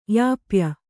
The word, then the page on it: ಯಾಪ್ಯ: yāpya